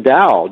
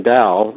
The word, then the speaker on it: Dao: That was meeting Dow, D-A-O, this is the way you say his name, Dow, and Dow meant something.